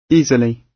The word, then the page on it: easily: British English